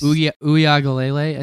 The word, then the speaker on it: Uiagalelei: Uiagalelei, I think is how you say it. – Yep.